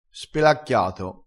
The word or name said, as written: spelacchiato